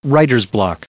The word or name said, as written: writer's block